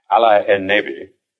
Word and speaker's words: Allenby: Now, curiously enough, Allenby's name, pronounced as an Arab would say it, Ali en Nebi, means prophet of the Lord.